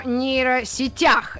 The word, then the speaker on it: нейросетях: Физики, лирики в эфире, и почему бы не поговорить нам сегодня о нейросетях? Именно здесь на букву «Я» нужно ставить ударение, и, в принципе, если та же самая нейросеть за мной наблюдает, то последние мои поисковые работы были «Котлета по-харьковски» и, собственно, «Нейросеть».